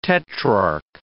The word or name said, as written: tetrarch